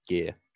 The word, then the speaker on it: Guia: Uh, and I really have no problem if someone like just asks me, and it's actually quite simple. It's just gear.